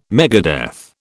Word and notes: megadeath: British English

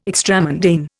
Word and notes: extramundane: Indian

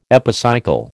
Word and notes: epicycle: American English

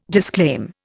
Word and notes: disclaim: Indian

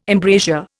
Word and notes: embrasure: Indian

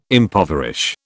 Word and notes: impoverish: British English

